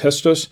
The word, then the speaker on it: Gestos: The Malbec, it's Gestos, G-E-S-T-O-S is pronounced Gestos.